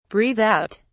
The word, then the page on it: breathe out: British English